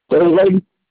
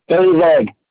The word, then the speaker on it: David: David. – Say that again. I'm sorry. – David.